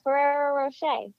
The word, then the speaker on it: Ferrero Rocher: Yeah, Ferrero Rocher.